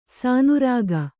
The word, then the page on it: ಸಾನುರಾಗ: kannada